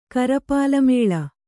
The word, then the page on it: ಕರಪಾಲಮೇಳ: karapālamēḷa